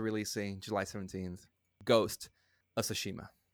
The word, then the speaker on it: Tsushima: Thank you for reminding me, because I saw it too, and I was like, okay, it's pronounced like this, Tsushima.